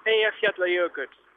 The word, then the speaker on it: Eyjafjallajökull: That's Eyjafjallajökull. – Yeah, well, you see, when you say it like that, it's fairly straightforward, isn't it? – It sounds quite melodic, in fact. – Eyjafjallajökull.